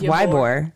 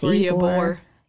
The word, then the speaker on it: Ybor: So you would think that's Ybor. – It's Ybor.